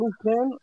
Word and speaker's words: Lupin: It's spelled Lupin, L-U-P-I-N, but it's Lupin, French.